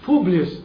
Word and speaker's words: Publius: Publius.